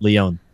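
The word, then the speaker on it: León: I always say Lyon. I was like, that's not right. Lyon. – Okay. Lyon. Not Leo. Not like Leo DiCaprio. Leo.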